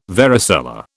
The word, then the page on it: varicella: British English